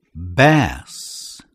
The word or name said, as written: bass